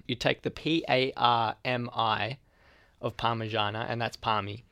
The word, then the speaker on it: Parmigiana: That is Parmigiana. Such an overpronounced mij. All right. Yeah, so one of my arguments here is that it's Parmigiana. That's how we say it in our accent.